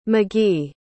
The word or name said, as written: mcgee